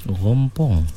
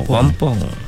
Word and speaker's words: rompong: Macam kita sebut lah, kalau kita eja dalam apa ejaan standard ni R-O-M-P-O-N-G lah. Ejaan dia R lah kan? Rompong lah. Tapi kalau sebut ni huruf R di depan bagi lorat Kedah, dia jadi R kan? Dia jadi rompong.